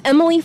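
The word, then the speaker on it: Emilie: And it's Emily for Oklahoma at all of those, except on LinkedIn, it's Emily Murphy Tindall.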